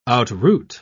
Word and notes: outroot: British English

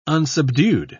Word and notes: unsubdued: British English

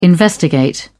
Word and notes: investigate: British English

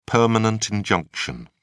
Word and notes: permanent injunction: British English

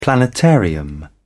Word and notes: planetarium: British English

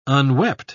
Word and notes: unwept: British English

